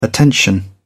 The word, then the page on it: attention: British English